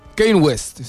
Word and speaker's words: Kanye West: Acá estuve viendo en las redes y aparece Kanye West. No sé si lo dije bien. – Kanye West. Sí, Kanye West.